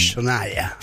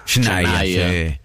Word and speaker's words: Shania: Sí, el nombre es Shania. – Shania.